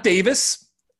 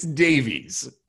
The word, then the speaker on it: Davies: Okay, it's not Davis It's Davies.